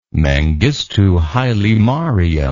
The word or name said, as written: mengistu haile mariam